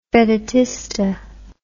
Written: vedutista

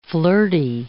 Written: flirty

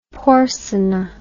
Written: porsena

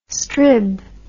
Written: strib

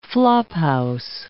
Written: flophouse

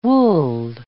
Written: woolled

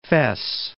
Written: Fes

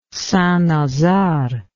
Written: St Nazaire

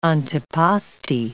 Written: antipasti

